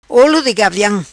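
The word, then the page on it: Olo Di Gaviam: patua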